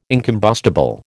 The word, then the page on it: incombustible: American English